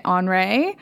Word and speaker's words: ON'RE: It's spelled O-N apostrophe R-E. And we're pronouncing it Henri.